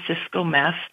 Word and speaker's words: Cisco Meth: The beautiful thing about my name is I am the only Cisco meth on the internet.